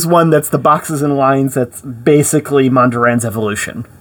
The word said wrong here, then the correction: Mondrian: It's pronounced Mondrian, just for the art nerds.